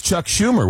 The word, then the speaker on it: Schumer: that was Chuck Schumer, was it not? – Yes. Yes. Yeah. The senator's name rhymes with rumor.